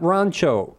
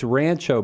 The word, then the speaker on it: Rancho: Yeah, it was in, not Rancho. I got corrected. It's Rancho. But see, I was, you know, I thought, ese, we pronounce it Rancho Cucamonga, man. – Yeah. – But they told me, no, it's Rancho. Because I guess we're white people. – that's very Anglo. It's funny in California. Some cities, the Spanish words are pronounced like in Spanish.